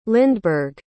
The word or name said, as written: lindbergh